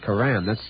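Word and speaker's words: Coram: I said Corum. That's how you're supposed to pronounce it. – Really? I didn't know that. I always thought it was Corum. – Well, actually, most people throughout the world pronounce it the way you said it. – Corum? – Right. But people on the North Shore of Long Island always call it Corum.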